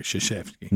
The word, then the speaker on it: Krzyzewski: I mean, there's nothing in this name written that looks like Krzyzewski.